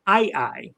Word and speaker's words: aye-aye: So there are no woodpeckers in Madagascar, but there's a very different animal called an ai-ai. It's spelled A-Y-E-A-Y-E, and if you've never heard of it, you should go Google some videos to see it.